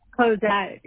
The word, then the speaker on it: Cosette: Is it, how you say it? Cosette? – Cosette, yeah. C-O-S-E-T-T-E. – E-T-T-E.